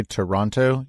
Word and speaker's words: Toronto: Well, one of the things that Toronto has to offer, well, first of all, you can't say Toronto. You have to say it like there's no first O. So it's just T-R-O-N-T-O, Toronto. That's how you have to say it when you get there.